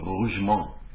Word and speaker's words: Rougemont: There was a French philosopher named Denis Rougemont. I pronounced it right because I know French.